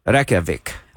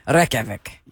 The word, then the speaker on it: Reykjavik: Reykjavik. – Reykjavik.